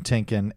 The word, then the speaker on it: Tienken: My last name is spelled T-I-E-N-K-E-N, so Kevin Tinkin, at Kevin Tinkin on everything.